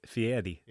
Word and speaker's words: Fieri: Guy Fieri? Am I even saying that right? – You gotta say Fieri. – Yeah. – Oh. – You gotta get the emphasis on the... Eddie.